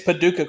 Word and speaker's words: Paducah: How do you fucking pronounce that? PADUCAH, Kentucky. – Oh, it's Paducah, Kentucky.